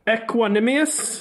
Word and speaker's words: Equanimeous: In 2019, and I was kind of hoping you would throw out the names, because the first one I've never really known how to pronounce, but Equanimeous. – There you go. – St. Brown. – Yeah, I believe that is how you pronounce it.